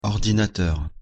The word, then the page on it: ordinateur: French